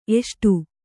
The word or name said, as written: ಎಸಡು